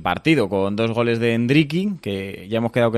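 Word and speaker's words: Endrick: La pronunciación es como con acento en la E. – Endriki.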